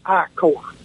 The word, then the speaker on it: I Corps: The northern part of South Vietnam was identified as I Corps, actually one corps, but always pronounced I Corps.